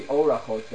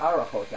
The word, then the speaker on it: Orachot: So this would be Orachot then. Or Orachot, whichever way you come with. But it's not Arachot, actually.